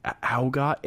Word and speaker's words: Augat: Okay, so Diane Augat, A-U-G-A-T, how would you say that? Augat? – Augat, yeah. – Augat? – Augat. – Augat. Okay, Diane Augat. – Or would that just be Ought? A-U-G-H-T? – No, A-U-G-A-T.